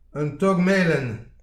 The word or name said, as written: un tok melen